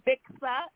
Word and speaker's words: Bixa: You can follow me. Also follow my plant-based vegan lip line from Africa. It's Bixa. It's spelled B-I-X-A, Bixa Beauty.